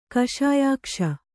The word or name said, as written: ಕಷಾಯಾಕ್ಷ